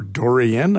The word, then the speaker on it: durian: And the answer is durian, or dorian, I've heard it pronounced.